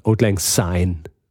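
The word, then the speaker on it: Auld Lang Syne: dann ist es natürlich Old Lang Sign oder Old Lang Sign, wie man es eigentlich aussprechen muss.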